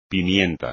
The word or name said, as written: pimienta